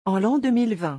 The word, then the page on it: en l'an 2020: French